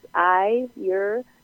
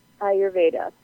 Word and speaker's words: Ayurveda: So Ayurveda, you pronounce Ayurveda, like I as in the eyeball, Ayurveda.